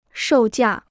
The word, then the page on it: 售价: shòu jià